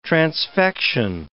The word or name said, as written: transfection